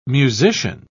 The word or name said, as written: musician